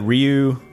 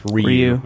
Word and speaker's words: Ryu: I think apparently it's really technically supposed to be pronounced Ryu. But they pronounced it Ryu in the game and in the movie.